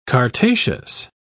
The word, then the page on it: chartaceous: British English